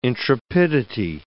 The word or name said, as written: intrepidity